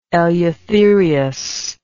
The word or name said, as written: Eleutherius